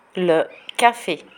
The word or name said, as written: le cafe